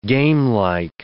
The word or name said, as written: gamelike